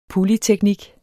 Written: polyteknik

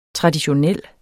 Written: traditionel

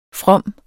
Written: fromme

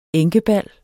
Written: enkebal

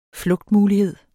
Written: flugtmulighed

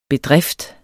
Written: bedrift

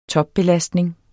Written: topbelastning

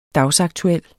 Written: dagsaktuel

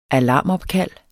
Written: alarmopkald

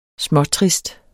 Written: småtrist

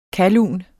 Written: KALLUNERNES

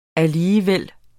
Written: alligevel

